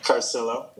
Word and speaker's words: Carcillo: Is that the right pronunciation? Carcillo? Carcillo. – Almost, yeah. Carcillo. Yeah. – Carcillo. Okay. I think Carcillo is Argentinian. – Yeah, that's the European way. Carcillo is the Italian way.